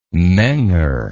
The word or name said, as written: menger